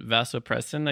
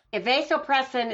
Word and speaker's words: vasopressin: So oxytocin is the anti-divorce drug, and then vasopressin again is what? – Vasopressin, it's pronounced both ways. Vasopressin, vasopressin. It helps with bonding.